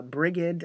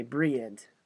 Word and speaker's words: Brigid: If you look at it, it's the English spelling is B-R-I-G-I-D. And you might think, well, that kind of looks like Bridget. But it's not Bridget. It's the hard G. So Brigid.